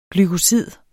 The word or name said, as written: glykosid